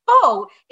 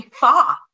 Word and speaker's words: pho: But something like what I'm describing as faux is actually mispronounced. So if I said to someone who happens to know Vietnamese food or be of Vietnamese background, they might correct me and say, it's actually pho.